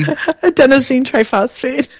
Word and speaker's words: adenosine triphosphate: Adenosine triphosphate.